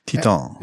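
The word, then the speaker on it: Titane: Titan? I think you would say Titan. – It's French. The movie is French, so that's how you would pronounce it. – Titan.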